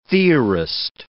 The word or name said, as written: theorist